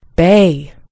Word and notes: bay: American English

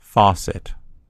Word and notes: faucet: American English

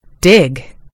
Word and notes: dig: American English

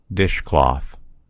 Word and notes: dishcloth: American English

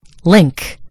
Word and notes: link: British English

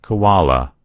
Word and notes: koala: American English

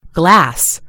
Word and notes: glass: American English

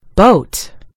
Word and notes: boat: American English

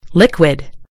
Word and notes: liquid: British English

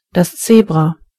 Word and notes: Zebra: German

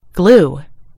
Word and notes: glue: British English

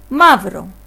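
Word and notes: black: Greek

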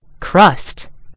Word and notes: crust: British English

